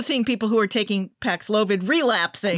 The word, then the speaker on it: Paxlovid: And there's Paxlovid. I don't know how you pronounce it. Paxlovid or Paxlovid.